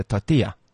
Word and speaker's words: tortilla: Can I please have a tortilla? It's a tortilla. – Tortilla. – Because the double L becomes a Y.